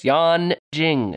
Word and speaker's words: Yanjing: Number six, Yanjing. It's spelled Y-A-N. J-I-N-G. – Just like it sounds.